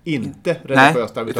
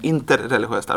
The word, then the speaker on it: interreligiöst: Alltså det vet jag väl, men jag har sett framför mig att interreligiöst arbete, då sitter alltså, det du säger nu är inte interreligiöst. – Bra, jag ska vara noga med betoningen, interreligiöst arbete.